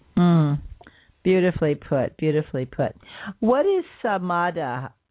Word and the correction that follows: Samadhi: Am I pronouncing it right? S-A-M-A-D-H-I? – Oh, Samadhi? – Samadhi, yes.